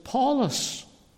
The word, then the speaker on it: Paulus: It's called the deputy here, Sergius Paulus. Just Paul, the way the Latin pronunciation of Paul.